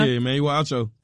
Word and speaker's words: Emmanuel Acho: Yeah, Emmanuel Acho.